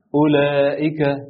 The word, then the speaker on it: أولئك: الدايرة لو شفتها في المصحف على الحرف هيبقى معناها إن الحرف ده كأنه مش موجود وما بيتنطقش، زي مثلا أولئك، الواو عليها دايرة وإحنا فعلا مش بننطقها.